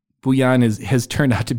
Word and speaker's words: Bouillon: So it's Bouillon, as in Bouillon Cube.